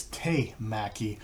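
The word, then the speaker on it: temaki: I just noticed something while I was editing this is that I mispronounced what the hand roll is. The hand roll is te-maki because tekamaki, if I remember correctly, is tuna. Teka is tuna.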